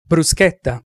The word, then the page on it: bruschetta: Italian